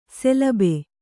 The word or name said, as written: ಶಲಭ